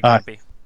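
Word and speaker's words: Kampe: And yeah, he's a great camp, a good coach, Campy, I think is how you say it. – Yeah, Greg Campy.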